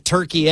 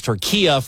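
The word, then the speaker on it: Türkiye: I know that we're calling it Turkey, and they used to be Turkey, but a week ago I had people correcting me on how to say the name of the country, especially people who are Turkish. And it is Turquia from their tongue, and I'm going to go with that.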